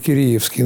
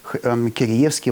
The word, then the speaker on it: Киреевский: Это у Киреевский, прошу прощения, вот это вот ударение, да, как Мусоргский или Мусоргский, так сказать, все говорят по-своему. Но мы вот будем сегодня говорить Киреевский, наверное.